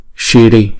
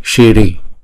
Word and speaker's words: shiri: Và câu tiếng Phạn là Shiri, Shiri. Chữ S có dấu sắc ở trên đầu chúng ta đọc là Ch. Chúng ta ấn cong cái lưỡi lên và đọc là sh, shiri shiri.